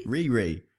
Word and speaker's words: Riri: R-I-R-I. How do you say that? – Riri? – Riri.